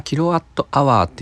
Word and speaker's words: kWh: 単位が kWh になってるとこでまあ、キロワットアワーって読む